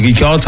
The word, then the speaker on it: Richard: Donc R-I-C-H-A-R-D. Hein. J'ai prononcé Ricard. Ça vous va? – Oui, on peut dire. Oui, y a pas de souci. Ricard, ça va. C'est de l'espagnol.